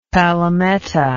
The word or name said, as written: palometa